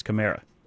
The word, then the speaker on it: Kamara: Maybe if the Saints are in this year's Super Bowl, maybe it takes that for people to figure out that Alvin Kamara's name is pronounced Kamara. – Yeah, I think we finally figured that out, and we tell everyone it's like Camaro. Like the car, like the old T-top, like in the 80s when you're drag racing and exhaust fumes are flying everywhere.